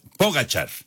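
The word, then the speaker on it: Pogačar: Es Pogacar. Ya nos decía Memo García y ya lo estábamos checando también acá en diferentes páginas. Pogacar, la pronunciación correcta.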